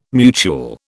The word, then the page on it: mutuel: British English